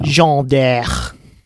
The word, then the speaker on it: Jeanne d'Arc: Jeanne d'Arc.